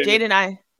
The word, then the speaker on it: Jaden Ivey: Jaden Ivey.